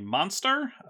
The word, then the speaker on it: Monztre: also have the booster fun version to show off, with art by Monster, that's spelled M-O-N-Z-T-R-E, which shows Oba using this magic to spin a kind of tangle of vines around her.